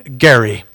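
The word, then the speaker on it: Gerry: And by the way, purists point out that the governor for whom gerrymandering is named actually pronounced his name Gary.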